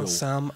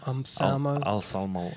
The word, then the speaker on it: Anselmo: Anselmo. – Anselmo.